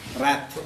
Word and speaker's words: wrath: The first point he makes is, lust is more subtle than wrath. Is it pronounced as wrath or wrath? What's the correct English pronunciation? – Wrath. – Wrath.